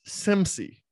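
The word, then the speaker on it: SEMSEE: So Philippe Charles Pierre, CEO and co-founder at SEMSE. That's, I don't know if I'm pronouncing the company correctly. It's S-E-M-S-E-E. So that's correct.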